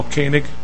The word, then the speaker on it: Koenig: My name is Mel Koenig.